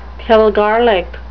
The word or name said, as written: pilgarlic